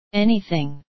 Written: anything

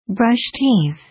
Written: brush teeth

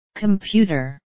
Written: computer

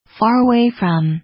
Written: far away from

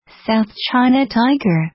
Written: south china tiger

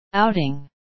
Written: outing

